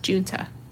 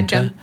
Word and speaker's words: Junta: her last name's pronounced Junta. – Oh. – What did I say? – Something not that. Like, Junta? – Yeah, Junta. Like June, Junta. Like the month.